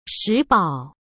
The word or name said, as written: Shi Bao